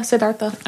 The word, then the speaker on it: Siddhartha: Yeah, Siddhartha.